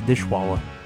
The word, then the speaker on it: Dishwalla: Dumblebee knows the pronunciation. He's telling me it's Dishwalla.